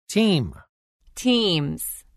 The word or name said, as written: Team – Teams